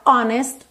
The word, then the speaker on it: honest: Essa palavra honest tem uma curiosidade, que esse H é mudo, o que não é comum em inglês, certo? Normalmente a letra H em inglês tem esse som h, h. Mas aqui na palavra honest, o H é mudo, então a gente tem an honest man, an honest man.